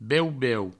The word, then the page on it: beu-beu: Provençal